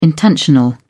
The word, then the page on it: intentional: British English